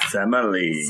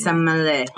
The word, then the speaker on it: Semele: I also want to point out that her name sounds like Smelly. – Yeah. – Like, well, the way I'm pronouncing it. – Semele. No, no matter how you... – Yeah. Semele. – Semele.